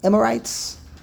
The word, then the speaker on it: Amorites: The nation of the Amorites? How do you say it? – Amorites.